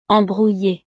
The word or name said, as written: embrouille